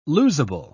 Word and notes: losable: British English

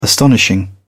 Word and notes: astonishing: British English

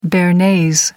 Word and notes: bearnaise: American English

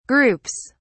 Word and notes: groups: British English

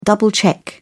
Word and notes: double-check: British English

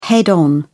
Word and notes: head-on: British English

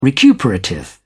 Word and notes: recuperative: British English

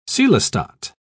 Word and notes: coelostat: British English

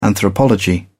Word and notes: anthropology: British English

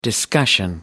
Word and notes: discussion: American English

